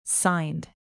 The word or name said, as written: signed